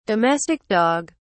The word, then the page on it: domestic dog: British English